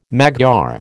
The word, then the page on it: Magyar: American English